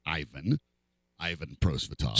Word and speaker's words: Prosvetov: And by the way, I did check up the actual NHL pronunciation. Yeah. At least according to the NHL itself, it is Ivan Prosvetov.